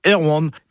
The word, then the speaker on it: Erwan: Mais la seule chose est sûre, Santerwan se prononce comme ça quand on parle de lui. Car il était originaire du Trégor, et là-bas on disait Erwan.